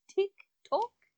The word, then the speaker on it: TikTok: And our resident internet famous person, you have what the children call a Tyke Talk. – Well, you're close. Good job, Mac. It's actually pronounced TikTok. – Oh, interesting. – Yes. – Like a clock.